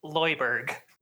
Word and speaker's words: Lewisburg: I believe it's pronounced Lewisburg. – Lewisburg, West Virginia.